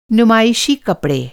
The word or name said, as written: नुमाइशी